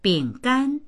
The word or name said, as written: bing3gan1